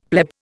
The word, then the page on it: pleb: Indian